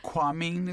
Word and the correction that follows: Kunming: it's called? – Kunming. – Kunming. – Yes. – Okay. – Perfect pronunciation. Kunming is the capital.